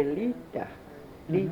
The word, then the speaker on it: Lita: And he used to say Lita, Lita. Mm-hmm. There's no R in pronunciation in Mi'kmaq.